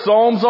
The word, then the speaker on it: Psalms: If you want to know where the Psalms are, first of all, the book starts with a P. It's the P Psalms. P-S-A-L-M-S. The P is silent, kind of like in Scarborough.